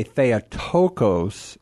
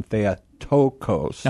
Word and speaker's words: Theotokos: Well, as they pronounce it today, Theotokos, it's normally you have the accent there on that omicron, Theotokos. – Uh-huh. – On the penult, right, before the final syllabus, or syllabus, the final syllable.